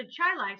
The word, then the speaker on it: Chili: There's a Chai Lai Street, only it's spelled like chili. – What? – Seriously, right? In Rochester. – Yeah. – Yeah. It's spelled like chili, but it's pronounced Chai Lai.